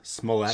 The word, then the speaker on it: Smollett: Smollett, Smollett.